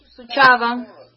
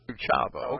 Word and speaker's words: Suceava: Suceava. – Suceava.